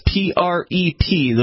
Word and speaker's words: PrEP: Yeah, just as you would discuss consent, condoms, PREP, those that apparently stands for something, PrEP.